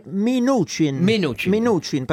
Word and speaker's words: Mnuchin: Ma c'era la traduzione esatta sul Financial Times che è Mnuchin, perché c'è una I che non è nella grafia e la U si legge U e non A.